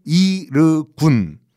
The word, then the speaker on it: 이르군: 참 시간이 이르군. 발음은 똑같아요. 이르군인데 유대인들이요 슬슬 이제 무력으로 팔레스타인 아랍인들을 깔아 뭉개기 위해 가지고 민병대를 조직을 해요. 그 민병대 이름이 이르군.